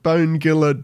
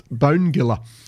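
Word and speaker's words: Bonegilla: And I pronounce it Bone Giller deliberately because there was an article in, I think it was either City, well, it was either City News or Canberra Weekly about this event. And it mentioned that the bloke who put it together, whose name I can't remember, did some research, and that included going to the Migrants Hostel Museum, where he was informed it is pronounced Bone Giller.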